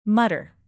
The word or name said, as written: mutter